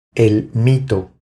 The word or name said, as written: El mito